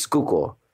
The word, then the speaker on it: Schuylkill: That's how that looks on paper, but it's pronounced Schuylkill.